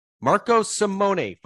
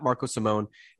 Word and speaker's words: Marco Simone: Marco Simone, folks, is how you pronounce it. Not Marco Simone, as I've been butchering on this podcast all year long.